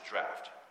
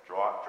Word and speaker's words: draught: or that is also pronounced draft. I guess it depends on where you're from. So I'll say both, draught, draft. Okay, it looks like draught to me, but I do know that many pronounce it draft.